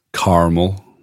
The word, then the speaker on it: caramel: It was caramel. There's an American thing. In Scotland, we say caramel. – See, there you go. There's your lesson. Caramel. – Caramel. With caramel. Proper syllables.